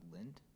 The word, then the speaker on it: Lindt: It might be a little old school, but I'm actually a really big fan of Lindt. – Okay. – I think that's how you pronounce it. I'm pretty sure. Yeah. – You got it. Yeah. Matt hates it. – I was going to say, Don't say lit.